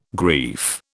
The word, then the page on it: grief: British English